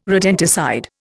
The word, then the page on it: rodenticide: Indian